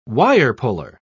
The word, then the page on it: wire-puller: British English